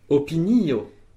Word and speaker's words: opinio: Opinio. – Opinio. – Opinio. – Opinio. – Une opinion. – Très bien pour l'accent tonique, sur le i de opinio, sur le deuxième i, donc.